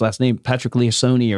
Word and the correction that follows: Lencioni: or Liaccioni. – Lencioni? – Lencioni, maybe. Third time's a charm.